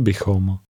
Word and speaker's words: bychom: Chtěli bychom. Bychom. Chom. Bychom. Bychom. Chtěli. Chtěli bychom. – Notice the m sound at the end.